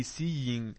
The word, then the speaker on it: seeing: كل مرة أكرر قلت seeing will be seeing. هل قلت seeing؟ هل نطقت الجي؟ لا، قلت seeing. كل مرة نكرر لما آخر الكلمة يكون ing لا تنطق، وأقرأها غنة غنة.